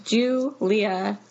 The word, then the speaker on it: Juleah: It's going to be Julia Kaliski. It's just my name. Super simple. It's spelled funny. J-U-L-E-A-H, Julia Kaliski, K-A-L-I-S-K-I.